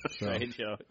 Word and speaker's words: Trejo: How I remember it is Trey is three, and Ho is Ho, so I'm all like three Hoes. You know? – But it's spelled Trejo. – Trejo, yeah. – In case you get confused, you spell that. – Oh, yeah, there's a J, but it's an H, and, yeah, it's stupid.